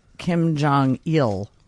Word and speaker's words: Kim Jong-il: It's a flower named after Kim Jong-il. – Yeah. – So ill, not eel.